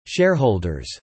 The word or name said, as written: shareholders